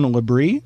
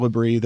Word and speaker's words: Labrie: And this is the case of Owen LaBrie, I believe is how this young man's name is pronounced.